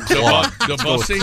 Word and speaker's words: Debussy: I believe it's pronounced. – No. – Debussy. Claude. – Claude. – That way you can't get it wrong.